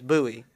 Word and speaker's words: Bui: Like my last name is Bui.